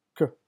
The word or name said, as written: k